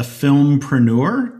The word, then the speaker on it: filmpreneur: He also describes himself as a filmpreneur. Did I say that right, Forrest? – You got it right.